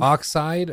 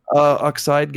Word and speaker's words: Oxide: Oxidi Games. Am I pronouncing that right, do you think? – Oxide, I think. – Oxide Games, yeah.